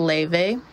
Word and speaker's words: Laufey: It's spelled Laufey, but it's pronounced Leve.